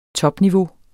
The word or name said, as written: topniveau